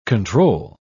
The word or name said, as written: control